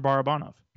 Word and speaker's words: Barabanov: It's Alexander Barabanov.